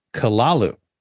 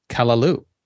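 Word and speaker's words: callaloo: You pronounce it kalalu. We pronounce it kalalu with the emphasis on the last syllable.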